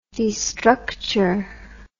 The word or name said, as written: destructure